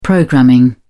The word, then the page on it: programming: British English